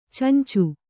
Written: ಚೊಂಚು

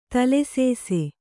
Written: ತಲೆಸೇಸೆ

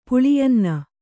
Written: ಪುಳಿಯನ್ನ

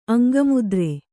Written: ಅಂಗಮುದ್ರೆ

